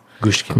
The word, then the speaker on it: Gushiken: If you're into boxing and the lighter weight classes, Gushiken Yoko. Gushkin. – Gushkin also. Yeah. How do you, spell that? – G-U-S-H-I-K-E-N, Gushkin.